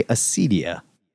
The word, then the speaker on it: Acedia: I'm going to say Acedia. A-C-E-D-I-A. Acedia.